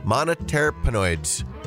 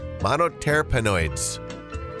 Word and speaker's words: monoterpenoid: Well, the new method involves adding molecules called monoterpenoids. Hopefully I said that right. Monoterpenoids. Computer, how do I pronounce this word? – Monoterpenoid. – Monoterpenoid.